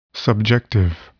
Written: subjective

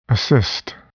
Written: assist

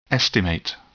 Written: estimate

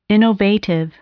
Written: innovative

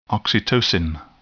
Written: oxytocin

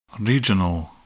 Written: regional

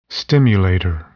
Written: stimulator

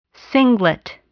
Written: singlet

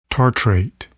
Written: tartrate

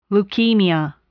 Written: leukemia

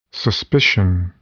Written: suspicion

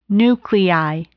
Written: nuclei

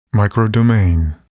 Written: microdomain

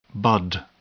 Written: bud

